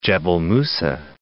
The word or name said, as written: Musa, Jebel